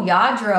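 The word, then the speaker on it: Lladró: Yeah, I sold a Yadro figure. – Oh, Yadro. I love that stuff. For the listeners, it's spelled LLADRO, and it's from Spain, I believe.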